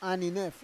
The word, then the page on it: aninef: Roon